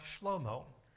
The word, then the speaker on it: Shlomo: Now, the name Solomon in Hebrew is pronounced Shlomo, and the bride in this book is pronounced Shulamit.